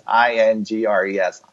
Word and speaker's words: Ingres: And say, and all right, and I'm from the South, so I'm gonna slaughter names, but it's Ingres. Is that how you say it? – Oh, gotcha. Right, yeah.